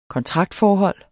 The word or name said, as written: kontraktforhold